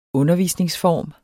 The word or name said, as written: undervisningsform